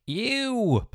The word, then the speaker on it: EWWW: I'm talking to Shane Bishop, who is the founder of the Ew plugin. It's spelt E W WW.